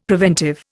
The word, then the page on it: preventive: Indian